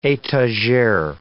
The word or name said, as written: etagere